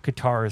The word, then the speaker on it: Qatar: Qatar is probably the easiest way to say it. Do not say Qatar, because I know a lot of people will come up to me like, hey, how is Qatar?